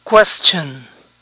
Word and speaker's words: question: Question. Question. Algunas personas tienden a pronunciar la S de esta palabra como SH. Question.